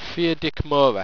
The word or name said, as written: pheardicmora